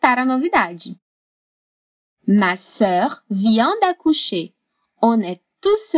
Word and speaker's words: sœur: Sœur. Essa palavra tem uma só sílaba e causa um pouco de dificuldade pelo e dans l'o, literalmente o e dentro do o.